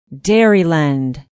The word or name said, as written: Dairyland